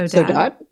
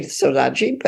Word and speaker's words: saudade: saudade, so saudade. Yeah. the Brazilians say it differently. They say Sodade, but um, dad is is a very important Portuguese word.